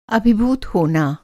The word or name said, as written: अभिभूत